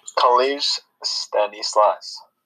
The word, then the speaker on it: Collège Stanislas: Collège Stanislas.